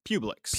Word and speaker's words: Publix: Publix. – Publix.